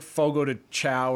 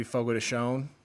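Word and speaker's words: Fogo de Chão: yeah, Or it's like fogo to chow, or but it's technically fogo to shone.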